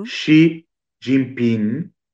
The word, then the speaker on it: Xi Jinping: Xi Jinping, non si legge la G finale,